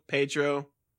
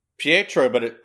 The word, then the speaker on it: Pietro: How do you pronounce it? Pietro? Pedro? – Pietro, but it took a long time.